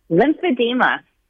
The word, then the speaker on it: Lymphedema: Lymphedema.